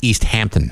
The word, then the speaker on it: East Hampton: Members of the Criminal Investigations Bureau and Narcotics Division were working alongside the East Tampon Police Canine Unit. – Steve, I believe it's pronounced East Hampton. – Have you ever been there? – I have. – It's pronounced the way it looks.